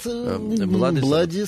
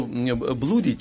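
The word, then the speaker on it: Bloody: И вспомнил, что Блуди читается как Блади, Double O.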